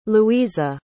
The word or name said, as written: louisa